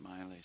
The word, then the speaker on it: Maile: It's spelled M-A-I-L-E, but pronounced Miley.